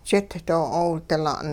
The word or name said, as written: jit doo'oodila'n